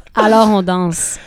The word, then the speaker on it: Alors on danse: Alors on danse.